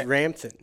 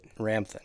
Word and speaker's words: Ramthun: I had a heavy U. It's not Ramthun. It's Ramthun. – Ramthun.